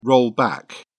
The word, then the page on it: roll back: British English